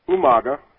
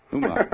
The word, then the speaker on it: Umaga: And could you please pronounce Umaga properly for the chat room? – Umaga. – Umaga. That's what I thought.